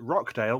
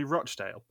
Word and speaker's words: Rochdale: She calls it Rockdale, but it's actually Rochdale.